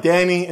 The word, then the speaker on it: Dani: Danny, which I spell it D-A-N-I, and my web identity or my company, which is called Simply Do It. Simply Do It. And you just write Danny and Simply Do It.